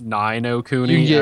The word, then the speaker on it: Ni no Kuni: Yeah, I believe it's like, pronounced Nino Kuni. Actually, you can get Dragon's Crown for like $17.49. Guacamelee for $7.34. Nino Kuni for $6.99.